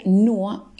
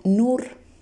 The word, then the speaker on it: nur: En podcasts anteriores aprendimos que la unión de la U con la R se pronuncia UA y no UR. Entonces la palabra NUA solo se pronuncia NUA y no NUR.